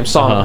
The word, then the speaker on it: Sonna: The first class I taught, this girl's name, it was spelled like Sauna. – Oh, God. – Like S-O-N-N-A. – That was one, like, the first name? – S-O-N-N-A was the first name. Sauna.